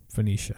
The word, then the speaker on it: Phoenicia: Phoenicia? – Yeah, Phoenicia.